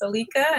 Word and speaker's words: Alica: And um, my name is Alica. It looks like Alicia on paper, but it's actually pronounced Alica, and you'll see it in the show notes.